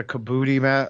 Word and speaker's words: kabuki: He's got the kabuki mask. Is that, if I'm saying that right? – Yep.